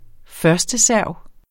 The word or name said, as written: førsteserv